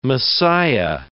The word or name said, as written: Messiah